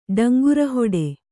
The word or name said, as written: ಡಂಗುರಹೊಡೆ